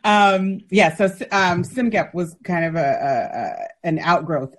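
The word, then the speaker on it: CSMGEP: That's how you say it. I didn't realize. – Yes, CIMGEP. – This is a part of the hidden curriculum, which is the acronym. – The way that the group pronounces the acronym. Because you never know from looking at it.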